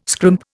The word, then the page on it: scrimp: Indian